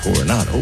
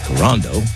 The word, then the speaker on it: Coronado: Last week, I mispronounced Coronado. I said Corando.